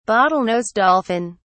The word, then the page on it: bottlenose dolphin: British English